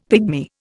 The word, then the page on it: pigmy: Indian